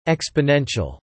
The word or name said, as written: exponential